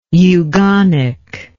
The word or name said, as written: eugonic